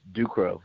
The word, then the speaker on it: DuCros: It's DuCros, so it's French.